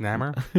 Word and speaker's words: Namor: It's pronounced Namor.